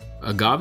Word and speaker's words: agave: And you're just a simple agave mm-hmm.